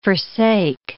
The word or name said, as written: forsake